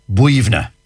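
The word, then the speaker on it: Boyounagh: Like, for example, I know one, for example, one cemetery near Glenamedy, and it's spelt B-O-Y-U-N-A-G-H. – B-O-Y. – So you'd be saying Boyuna. No, yeah. Not a bit of it. B-O-Y-U-N-A-G-H is pronounced Buivna.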